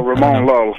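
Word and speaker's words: Ramon Llull: Oh, Ramon Lul.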